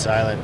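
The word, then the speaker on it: SLNT: And it's S-L-N-T? – Yep, but pronounced Silent.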